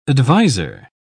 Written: adviser

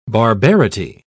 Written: barbarity

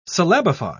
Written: syllabify

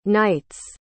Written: knights

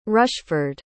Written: rushford

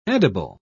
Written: edible

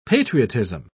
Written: patriotism

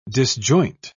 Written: disjoint

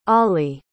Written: Aali

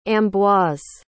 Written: ambroise

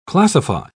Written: classify

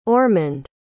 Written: ormond

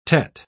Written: teth

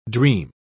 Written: dream

